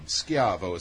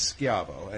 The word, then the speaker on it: Schiavo: Schiavo is actually what my last name is. – Oh, so it's not Shivo like Terry Shivo. – Well, it's actually spelt the exact same way, but it's mispronounced by the Americans.